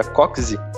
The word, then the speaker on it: Kocs: Elas identificaram que em 1500, numa cidadezinha da Hungria, o nome Cox K-O-C-S, que se pronuncia Coxy, era famoso pela produção de carruagens.